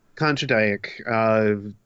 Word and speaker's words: Kondratyuk: Kondratyuk. With a little bit more Russian thrown in there, but yeah.